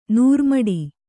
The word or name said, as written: ನೂರುಮಡಿ